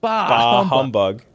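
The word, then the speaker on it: Bah humbug: Your emphasis is on the wrong syllable. – Yes, you're making it one word. – Yeah. – What is it? – Bahumbug.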